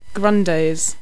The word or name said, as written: grundos